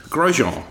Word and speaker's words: Grosjean: Grosjean. As far as I'm aware, it's Grosjean. Well, Gean in French is Jean, so I'm presuming the S is silent, and it's Grosjean.